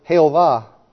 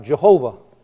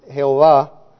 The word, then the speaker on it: Jehová: They use the word Jehová, which is like Jehovah. You see it in English as Jehovah. In Spanish it's pronounced Jehová.